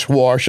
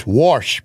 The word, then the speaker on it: Warsh: As you know, we're looking at a new Fed chair candidate, Kevin Warsh. Yes, Warsh. I'm not mispronouncing W-A-S-H. It is Warsh.